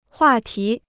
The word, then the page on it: 话题: huà tí